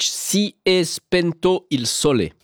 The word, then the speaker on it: Si è spento il sole: Adriano Celentano avec ce titre de 1962, choisi par Stefani Ivanovic, Si è spento il sole. Ça se prononce exactement comme ça.